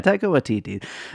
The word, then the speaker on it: Taika Waititi: today we are talking about Jojo Rabbit from Taika Waititi. Is that how you say his name? – Taika Waititi.